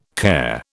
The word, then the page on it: care: British English